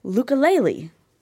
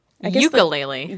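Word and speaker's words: Yooka-Laylee: Yeah, called Lukulele. – Ukulele.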